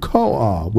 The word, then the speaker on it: koah: So this word power is the Hebrew word koah, which is spelled K-O-A-H. Which, you know, Noah, Koah.